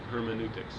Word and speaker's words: hermeneutics: Hermeneutics. – That's how you say it.